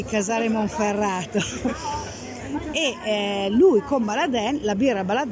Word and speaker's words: Baladin: Siamo allo stand Baladin, si dice Baladin, è scritto Baladin ma si dice Baladin. – C'è chi lo pronuncia in entrambi i modi.